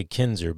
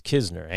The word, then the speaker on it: Kisner: I keep wanting to say Kinzer, but it's Kisner, Andrew Kisner.